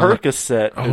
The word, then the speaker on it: Percocet: Parachet and Codeine, please don't take my... – Percocet is how you say that.